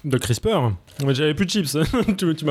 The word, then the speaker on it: CRISPR: Je vous préviens tout de suite que moi je prononce CRISPR et pas CRISPR. Donc, CRISPR, C-R-I-S-P-R, c'est un nouvel outil de biologie moléculaire qui ouvre la voie à la modification du patrimoine génétique in vivo.